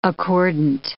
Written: accordant

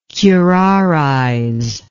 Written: curarization

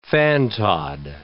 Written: fantod

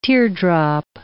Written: teardrop

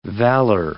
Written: valor

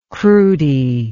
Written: crowdy

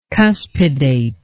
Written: cuspidate

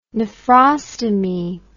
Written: nephrostomy

